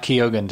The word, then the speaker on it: Keoghan: I caught Keoghan too.